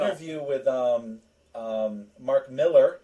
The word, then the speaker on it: Millar: And it was funny because while we were watching it, I kept telling my wife, I kept saying, it's Millar, but it's pronounced Miller.